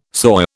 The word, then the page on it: soy: British English